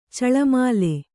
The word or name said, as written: ಚೆಲ್ಲುಮಲ್ಲಿ